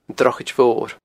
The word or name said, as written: Drochaid Mhor